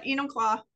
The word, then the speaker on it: Enumclaw: So I was at Enumclaw.